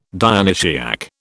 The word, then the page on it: Dionysiac: British English